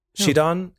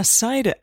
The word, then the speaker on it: Sidon: Shidon, he's the fish guy, right? – Oh yeah, Sidon.